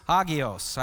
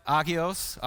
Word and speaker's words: agios: Agios? Agios? Yeah, he's going to get that. Probably means I'm not saying it right.